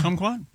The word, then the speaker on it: kumquat: And it is spelled K-U-M-Quat, right? – Yeah. – Kumquat? – Yeah. Yeah. Kumquat sounds, it's like the country of Guam.